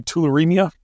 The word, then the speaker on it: tularemia: Am I pronouncing it right if I say tularemia? – That's it. Yep.